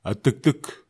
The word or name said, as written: atektek